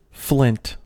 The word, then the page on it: flint: American English